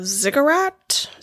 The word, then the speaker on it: ziggurat: So, the Tower of Babel, most scholars think it was a ziggurat. Is that how you would pronounce it? – That's how I would say it.